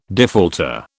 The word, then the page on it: defaulter: British English